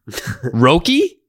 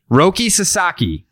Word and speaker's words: Roki Sasaki: I'm hoping I'm pronouncing this right. – Probably not. – Roki? – Yeah, there you go. – Roki Sasaki. – Yes, nailed it.